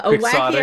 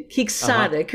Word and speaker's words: quixotic: It's a wonderful thing for the culture, and it shows that what started as a very quirky, quixotic, how do you say it? Like a quixotic. – Wacky idea, quixotic, uh-huh. Right? Uh-huh. It's Don Quixote, so.